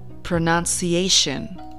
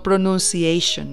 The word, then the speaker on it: pronunciation: La palabra se dice pronunciation, pronunciation, pronunciation. No pronunciation, no. Pronoun, pronoun. Pronunciation.